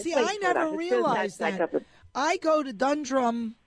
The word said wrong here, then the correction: Dundrum: I'm not putting the accent on the right syllable. It's Dundrum. Is that right? – Yes.